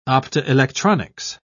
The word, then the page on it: optoelectronics: British English